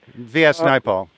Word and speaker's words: Naipaul: V.S. Naipaul.